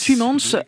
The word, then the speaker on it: Tuymans: Tuymans, artiste belge de premier plan, ulcéré par un projet de démantèlement du musée d'art contemporain de la ville d'Anvers.